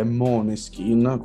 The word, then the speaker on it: Måneskin: Maneskin, ma la verità è che la pronuncia corretta, a giudicare dalla O sovrascritta nella A, dovrebbe essere Måneskin, come insomma un po' alla nordica, no? Alla svedese, eccetera.